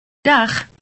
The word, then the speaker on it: dag: But it is pronounced slightly differently when it means goodbye. Listen. – Dag.